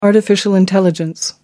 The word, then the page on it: artificial intelligence: American English